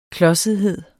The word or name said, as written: klodsethed